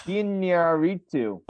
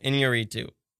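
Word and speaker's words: Iñárritu: Okay. – Inarritu. – Stop. – There we go. – Okay. – No, I'm saying it right. Inarritu. – Okay, yes. There you go. Inarritu.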